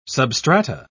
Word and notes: substrata: British English

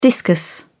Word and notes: discus: British English

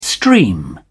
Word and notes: stream: British English

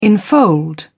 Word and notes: enfold: British English

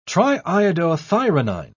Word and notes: triiodothyronine: British English

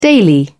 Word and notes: daily: British English

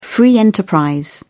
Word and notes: free enterprise: British English

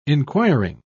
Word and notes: enquiring: British English

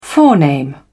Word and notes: forename: British English

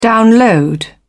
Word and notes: download: British English

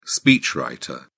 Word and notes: speechwriter: British English

